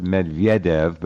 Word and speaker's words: Medvedev: Also, Medvedev, and that's how you pronounce it, Medvedev, but Medvedev works, whatever. Medvedev also... – No, you have it right. That's how the Russians pronounce it.